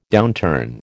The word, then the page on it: downturn: American English